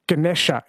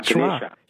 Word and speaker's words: Ganesha: Or they call the elephant god Ganesha in the south, Ganesh in the north. They delete the A's. – So the A at the end is pronounced like a schwa, Ganesha.